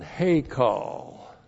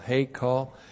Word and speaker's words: hekal: Then the preposition lameth, to or into, and then hekal. H, long E, K, A, L, hekal.